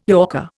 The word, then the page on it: yorker: Indian